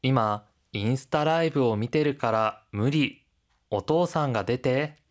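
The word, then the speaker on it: 미테르 (見てる): 그런데 여러분, 미테 이르가 아니라 미테르라고 표기가 되어 있잖아요. 테 이르는요, 회화체에서는 이가 생략되고 테르와 같이도 발음한답니다.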